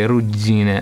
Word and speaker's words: ruggine: No, esatto, guarda, da noi a Reggio ruggine si pronuncia così, ruggine, a denti stretti, oppure in dialetto persino è rosna.